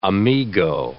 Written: amigo

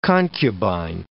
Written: concubine

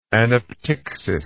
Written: anaptyxis

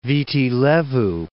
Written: Viti Levu

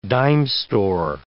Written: dime-store

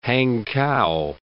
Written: Hankow

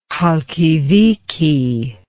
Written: Khalkidike

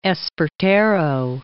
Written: Espartero

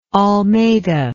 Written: almeida

